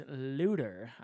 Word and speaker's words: Luder: Luder it wasn't actually Luther. The original pronunciation was Luder.